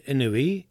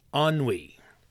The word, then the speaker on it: ennui: So when I read inui, I pronounce it like the Hawaiian of each letter kind of individually. Each vowel is sounded individually. And I said inui. It is pronounced ennui. Again, it is a French word.